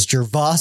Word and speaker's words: Gervais: Maybe it's Gervais, which begged the question, does it even start with a J sound? Is it Gervais? But no, it is Gervais.